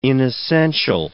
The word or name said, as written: inessential